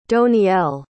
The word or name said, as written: doniel